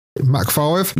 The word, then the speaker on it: Mach: It's M-A-C-H. Is that Mach 5? – Yeah. – Mach 5? – Mm-hmm.